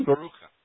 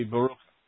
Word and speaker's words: Baruch: Baruch. It's the your name pronounced in Hebrew phonetically off of your spelling would be Baruch. – Baruch, which is blessed.